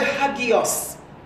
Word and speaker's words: hagios: In the Greek, the word holy is the word hagios. We like to spell it H-A-G-I-O-S.